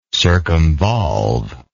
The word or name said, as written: circumvolve